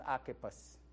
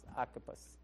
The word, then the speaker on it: Archippus: The brother's name is Archippus. That's really what it is. All right? That's how you pronounce it. It's Archippus. All right.